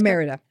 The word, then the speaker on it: emerita: Am I pronouncing this? – Emerita.